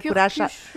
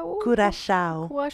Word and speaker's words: Curaçao: Curaçao. Curaçao. – Parce que c'est portugais, Curaçao.